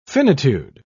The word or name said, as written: finitude